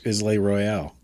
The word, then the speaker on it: Isle Royale: Now all the wolves are going to be going over the Isle Royale eating moose. – Islay Royale. – Sorry, I mispronounced it.